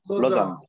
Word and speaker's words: Lausanne: Lausanne.